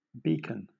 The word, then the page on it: beacon: British English